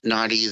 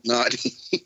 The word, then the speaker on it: Noti: In the first Naughty that, which it's spelled N-O-T-I, by the way, the first one that Sabine came across, it said Little Naughty, and I thought that was the name of the creature.